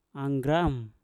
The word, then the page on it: anggram: mpur